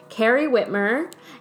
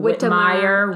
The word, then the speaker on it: Whitmer: I was going to say, you said it exactly how it's spelled. – Does anyone not? – Yes. People add many syllables. I've gotten Whitmire, Whitmer. – God, people are just Whittemore, dumb.